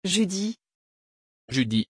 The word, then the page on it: judie: French